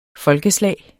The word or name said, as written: folkeslag